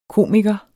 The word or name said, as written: komiker